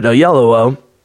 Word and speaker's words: Oyelowo: One of my favorite modern actors, who I'm finally able to pronounce his name after a couple of years, David Oyelowo,